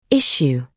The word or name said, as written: issue